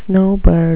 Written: snowbird

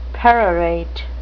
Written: perorate

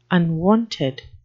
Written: unwonted

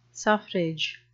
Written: suffrage

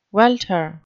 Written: welter

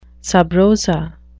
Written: sub rosa

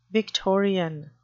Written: victorian

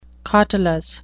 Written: cautelous

